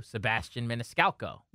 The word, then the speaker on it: Sebastian Maniscalco: Sebastian Maniscalco. – Yeah. – Did I say his name right? – You did. You nailed it.